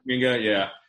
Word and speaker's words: Kuminga: and that's Jalen Suggs or Kumonga, is that how you say it? – Kuminga.